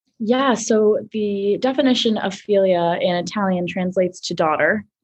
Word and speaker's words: Figlia: So it's actually pronounced Philia, but you shouldn't feel bad because most everybody calls it Philia and also thinks that there's figs in it.